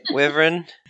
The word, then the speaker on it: Wyvern: Wyvern. Wyvern. What have I been saying? Wavrin? – Wavrin. – Yeah, I put an O in there for some reason.